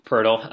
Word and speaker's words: Poeltl: You also still get Ginobili, Jakob Poeltl.